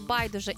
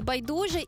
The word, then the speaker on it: байдуже: і тепер «помилка» і «помилка» абсолютно рівноправні варіанти. Так само у слові «байдуже» і «байдуже», і у слові «фейсбук» чи «фейсбук». Наголос іде на два, подвійний наголос на два різні склади.